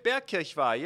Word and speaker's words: Bierkirchweih: It's home of the Bierkirchweih Beer Festival. Did I say that right? – You did, Bierkirchweih.